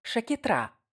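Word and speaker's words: Sciacchetrà: Называется это вино «Шакетра», с ударением на последний слог.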